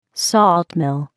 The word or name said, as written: salt mill